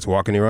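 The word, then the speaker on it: Tawakoni: Am I saying Tewakini right? – You're saying it right? Okay, yes, sir. You're good. Sure. Yeah, you're good.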